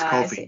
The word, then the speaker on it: Ko-fi: You know, I looked up how to pronounce it. – And it's not Ko-fi? – No, it's Ko-fi. It says right on their website.